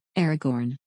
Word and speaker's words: Aragorn: Aragorn. There's an R in there.